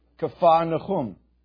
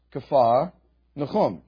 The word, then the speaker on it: Capernaum: Who on an Israel trip can tell me how it's really supposed to be pronounced? Go ahead. Close. Worse. Andrew was very close. Kafar Nechum. Kafar Nechum.